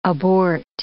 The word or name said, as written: abort